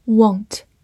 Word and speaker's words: want: Won't, W-A-N-T,